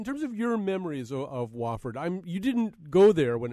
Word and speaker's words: Wofford: It's actually pronounced Wofford, W-O-F-F-O-R-D. I've heard Wafford, I've heard Wofford, I've heard Wofford, but it's Wofford, I assure you.